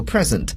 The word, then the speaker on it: present: 下面我们有看到付给某人 present。今天我们学到这个词的时候，大家可能觉得这个发音很特别，不像大家以前学到礼物这个词呢，它要读作 present，可是今天呢却读作了 present。